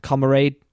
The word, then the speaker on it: camaraderie: Especially with the free-flowing conversation, the non-scripted dialogue, and the comrade... Oh, how do I say that word? – Camaraderie.